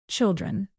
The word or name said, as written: children